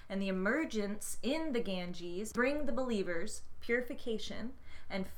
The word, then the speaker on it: Ganges: Ganges.